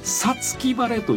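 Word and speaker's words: 五月晴れ: さつき晴れという言葉があります。はい。これは 5 月に晴れると書いてさつき晴れって読むんですけれども、はい、実はこの 5 月、まあ、旧暦で言いますと 6 月になるわけですよね、はい。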